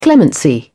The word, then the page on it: clemency: British English